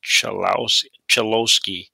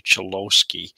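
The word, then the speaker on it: Cholowski: And I believe it was a former wing, Dennis Chalowski. We've been saying that name wrong all along. It's actually Chalowski.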